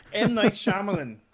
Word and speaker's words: Shyamalan: M. Night Shyamalan.